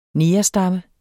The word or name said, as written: negerstamme